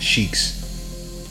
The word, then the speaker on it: Sikhs: Sikhs, did I say that right? – Sikhs. – Like Sheik Luch? – Like Sheik Luch.